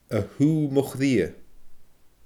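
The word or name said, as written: a shùgh mo chridhe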